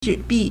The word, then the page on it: 纸币: zhǐbì